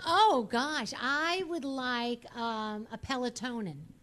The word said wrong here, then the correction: Peloton: Peloton? – Yeah, Peloton. Is that how you say it? – I was like, is that a medicine?